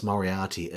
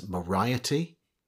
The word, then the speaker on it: Moriarty: he would pronounce Moriarty as Moriety.